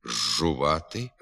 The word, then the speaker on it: зжувати: Пишеться «зжувати», «з жиру», «з джерела», «з джунглів», а вимовляти треба «жувати», «з жиру», «з джерела», «з джунглів».